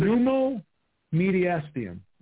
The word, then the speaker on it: pneumomediastinum: According to Lopez's medical records, the 24-year-old former undisputed champion was diagnosed with pneumomediastinum. I think I said that right. With extensive pneumomediastinum. – Okay.